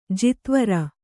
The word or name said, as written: ಜಾತಿವೈರ